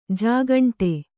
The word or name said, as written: ಜೇಗಂಟೆ